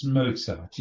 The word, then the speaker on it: Mozart: It's Mozart, yes it is.